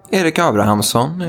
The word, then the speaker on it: Erik Abrahamsson: Erik Abrahamsson, if you're Swedish, and yes, I'm pronouncing it.